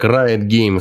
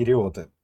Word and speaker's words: Riot Games: Riot Games, запишите, пожалуйста, сейчас мою просьбу произносить как Riot Games. Почему Riot Games, а не Риоты?